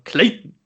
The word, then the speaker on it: Clayton: Und Du musst den Namen anders aussprechen. Du musst sagen Clayton.